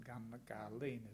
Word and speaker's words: Gungahlin: Not Gungahlin, Gungahlin is the proper pronunciation and spelling.